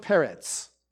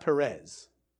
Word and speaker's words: Peretz: And then, again, you read then at the end, now these are the generations of Peretz. And that's how you pronounce his name, by the way, like with a T-Z at the end. It's not Perez. Okay? Peretz. Okay? That's how you say it.